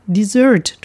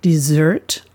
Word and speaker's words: Dessert: Dessert auf der zweiten Silbe betont und mit zwei S geschrieben, Nachtisch, was im Deutschen fast genauso ausgesprochen wird, nämlich Dessert, aber mit stimmlosem S, wie auch im Französischen, wo das Wort herkommt.